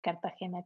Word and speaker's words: Cartagena: Es Nash Cartagena con G de gato.